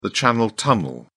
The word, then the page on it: channel tunnel: British English